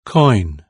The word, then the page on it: coin: British English